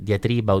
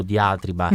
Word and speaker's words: diatriba: quindi la famosa dia- de- diatriba o diatriba, a seconda se si vuole dare l'accento greco.